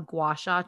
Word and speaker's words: gua sha: I have wanted a gua sha tool for ages.